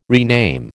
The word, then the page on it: rename: American English